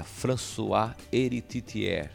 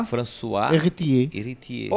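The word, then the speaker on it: Françoise Héritier: O Pierre Levy, é, uma mulher, uma francesa, Aí a galera depois pode me corrigir, mandar uma mensagem se minha pronúncia está correta, mas é François Héritier. – Oh, não, mandou bem. Você viu? É isso Sava mesmo. bien? François. Yeah. François, François Héritier.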